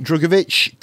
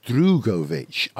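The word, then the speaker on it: Drugovich: Actually, I don't think you say his name Drugovic. I think it's pronounced Drugovic, I think, because I heard him say it once on an interview and he said Drugovic, not Drugovic.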